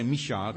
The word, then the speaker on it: Michal: Now, I say Michal because I believe that's how the Hebrew would pronounce it.